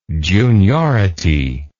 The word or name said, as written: juniority